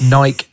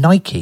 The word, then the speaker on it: Nike: Before we carry on, are you saying Nike? – Yes, I am. – Why are you saying Nike? – Because I believe that's the correct pronunciation. – There's no Y in it.